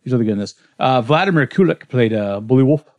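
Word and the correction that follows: Buliwyf: the heir apparent. How did you say it? – Bullvi, thank you.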